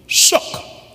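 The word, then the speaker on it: sook (שׂוּךְ): He said a hedge is pronounced in the Hebrew sook, S-O-O-K.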